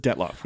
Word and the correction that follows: Dyatlov: And we've been pronouncing that wrong, though. – I'm still pronouncing it like I've always pronounced it. – Josh Gates pronounced it, and I can't even think... – Dyatlov, or something like that.